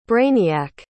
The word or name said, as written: brainiac